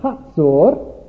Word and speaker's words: Hazor: And so the Lord sold them again into the hand of Jabin, the king of Canaan, who reigned in Hazor. If you go on an extended tour of the Holy Land, you may be taken to Hazor. The guide will probably pronounce it Hatzor.